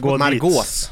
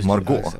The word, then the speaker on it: Margot: Margås. – Ditz? Margås? – Margås. – Margås? – Nej. Inget S. – Det är tyst X. – Ja, Margot. Margot Ditz.